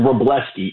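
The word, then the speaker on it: Wrobleski: I hope I said that correctly. I did not realize this guy's 5-0 with a 1.25. What do you see out today's pitching matchup? – So, for one, the thing for Bryce Elder, he's been really, really good this year ever since he added that cutter, and a lot of what he's doing is sustainable. The interesting thing about Justin Robleski, it was tough. I had to ask somebody the right way to say it,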